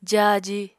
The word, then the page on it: jáaji: apatani